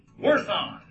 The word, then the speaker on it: worthon: Well, the word worship comes from the old Anglo-Saxon word worthon. Worthon. To declare how much something is worth. Worthon. Pronounced worthon. Yeah, nurse has nothing on me.